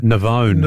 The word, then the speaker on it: Navone: But, yes, he was playing in the fifth set in the US Open against he might have been Argentine, Mariano, how do you pronounce his surname? – Navone. – Navone, is it? Mm.